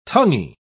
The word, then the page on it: tonguey: British English